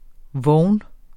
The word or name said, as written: vogn